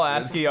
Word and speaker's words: ASCII: Did you go to the IGN walkthroughs where it's like everything is made out of, like, is it pronounced ASCII art? – Yeah, yeah, yeah. It's all ASCII art, and you're like, here you are.